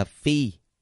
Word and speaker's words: phi: Chữ phi này ở đây đọc là phi.